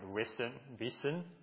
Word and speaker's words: wissen: So vijja, this word vijja, also related to, in Germany, you have the word wissen, how do you pronounce it? Wissen, that's it.